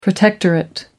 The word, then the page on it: protectorate: American English